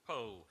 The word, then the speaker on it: Powell: This is the diary of the uh, somewhat snobbish uh, English novelist Anthony Powell, pronounced Pole.